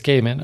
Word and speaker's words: Gaiman: Both of them are written by Neil Gaiman, I'm going to guess is how it's pronounced. – Neil Gaiman. – It's Gaiman. Okay. – Shame G-A-I.